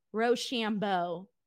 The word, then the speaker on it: Rochambeau: So after this, Ricky Starks hits the Rochambeau. Am I saying that right? Rochambeau. Yeah. And he hits it.